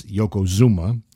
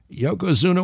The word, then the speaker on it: Yokozuna: Uh, it's between Yokozuna, who I've already tried to make a funny by imitating his manager, Mr. Fuji, who mispronounces his name as Yokozuma. But uh, Yokozuna walks into the ring, and here's my opening salvo.